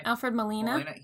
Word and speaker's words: Alfred Molina: Alfred Molina.